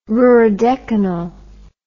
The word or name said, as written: ruridecanal